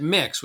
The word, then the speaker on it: Myx: And then we met the folks at Mix, which is spelled M-Y-X,